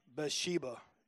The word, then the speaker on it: Bathsheba: So in 2 Samuel 11, yeah, it's 11, um, there's this sin that David commits with this woman named Bathsheba. It's funny, her name's Bath Sheba. That's how it looks. I've always heard people say Bathsheba, so I'm just thinking the H is silent, but it looks like Bath.